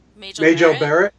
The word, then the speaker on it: Majel Barrett: Majel Barrett? – Yeah, Majel Barrett.